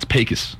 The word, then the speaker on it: Pecos: Did you also notice how he said Pecos? It's Pecos. Say it, play it again. It's how they say it there. They don't say it like Pecos.